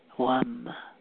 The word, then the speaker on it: Vam: And as you focus on the chakra, with each inhalation and exhalation, bring in the Bija mantra, Wam, Wam, Wam. V-A-M, but the is pronounced wa, and it's really more of a wang, wang.